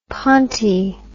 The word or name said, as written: ponti